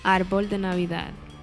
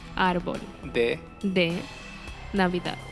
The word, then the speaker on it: árbol de Navidad: Árbol de Navidad. – So, árbol. – Árbol. – De. – Navidad.